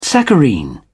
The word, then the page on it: saccharine: British English